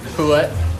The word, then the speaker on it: Poulet: So what's the right pronunciation for your shop? – Poulet. – Poulet? – Yeah. – Is it French? – Yeah. I think it's French for chicken.